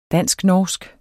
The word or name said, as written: dansk-norsk